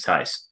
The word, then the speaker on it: Theis: And you may be noticing, if you've looked at the roster, it is spelled T-H-E-I-S. It is pronounced Tice, we have confirmed.